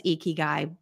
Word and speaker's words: ikigai: The author says ikigai, but other people have a different pronunciation. So yeah. – we're going to go ikigai. If you say ikigai, you're still welcome to listen and we would still want to hear from you, but mm we're following the author's lead.